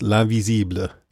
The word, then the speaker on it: L'Invisible: I was waiting to see how you pronounce that. I had to be reminded because I took French, but I wouldn't have gotten it right. But then I listened to it and it was L'Invisible.